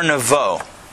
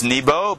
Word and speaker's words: Nevo: The Torah says, Har Nevo. In English, it's Nebo, but in Hebrew, it's Nevo.